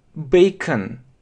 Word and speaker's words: bacon: И здесь, смотрите, не читается не con, а can, can, как E, слабое E, can, bacon, bacon, bacon.